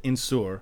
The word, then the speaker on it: Nsour: So our name, the way we spell it, is N-S-O-U-R, and we pronounce it Insur.